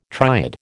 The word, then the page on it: triad: American English